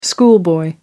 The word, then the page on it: schoolboy: American English